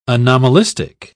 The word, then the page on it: anomalistic: British English